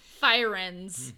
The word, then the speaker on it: Firenze: Today we'll be talking about Florence, or as the locals say, Firenze. – Yeah, that's pretty good, I think. I don't know if that's right or not. Who knows? – Firenze. That's how they say it.